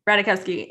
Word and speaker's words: Ratajkowski: Ratajkowski is actually really good.